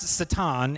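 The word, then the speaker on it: Satan (שטן): It's actually pronounced Satan in the Hebrew, or Ha-Satan, or literally in the Hebrew, this translates to the Satan.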